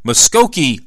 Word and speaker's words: Muskogee: I really had to think hard how to pronounce that, but the easiest way to remember how to pronounce that is the old Merle Haggard song, Okie from Muskogee.